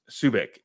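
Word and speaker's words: Sejic: Subic is the way you pronounce it.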